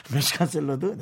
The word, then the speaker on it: 샐러드: 멕시칸 샐러드, 네, 멕시칸 사라다라고 죄송합니다. – 네, 옛날 분이시네요. 이 일본식 발음을 죄송합니다.